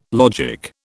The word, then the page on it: logic: British English